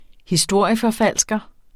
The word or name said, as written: historieforfalsker